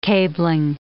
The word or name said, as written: cabling